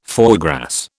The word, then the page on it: foie gras: British English